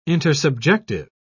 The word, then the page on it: intersubjective: British English